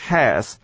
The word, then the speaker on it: has: 两个地方，第一个地方是 Your English has， has 这个字呢本身是一个助动词，这个声音啊不能念重的，所以通常呢 HAS 的 H 都不发音，不发音会怎么念呢？变成 AS 对不对？跟前面的 English 合在一起，我们做 Your English is really, your English is really, 各位听到没有？很像 IS 的声音，是这样念的哦。